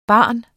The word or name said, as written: barn